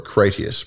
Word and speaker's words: Critias: Or Critias, probably. That would be the more Roman pronunciation of it.